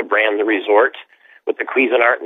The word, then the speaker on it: Cuisinart: Cuisinart. It's the same as the people who make the cooking devices.